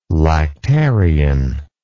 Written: lactarian

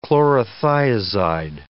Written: chlorothiazide

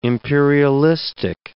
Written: imperialistic

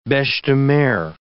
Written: beches-de-mer

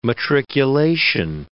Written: matriculation

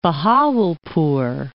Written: Bahawalpur

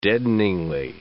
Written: deadeningly

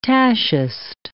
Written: tachist

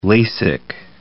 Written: LASIK

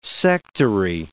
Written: sectary

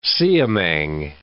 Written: siamang

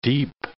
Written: deep

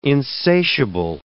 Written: insatiable

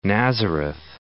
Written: Nazareth

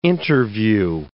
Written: interview

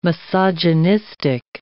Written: misogynistic

